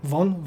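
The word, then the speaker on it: Vaughn: miałem ogromne oczekiwania po poprzednim filmie, Y, Matthew, powiedz mi jak się wymawia jego nazwisko, Matthew Vogue? – Ten Von? – Von? Von, okej. Okay.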